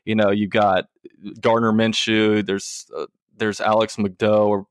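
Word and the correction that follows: McGough: or whatever from Magoo. – Okay. I was going to say, I never knew how to pronounce it, but he went to FAU or FIU? – He went to FIU.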